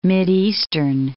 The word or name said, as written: Mideastern